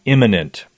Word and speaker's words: immanent: He is not immanent. And that's spelled I-M-M-A-N-E-N-T. Immanent.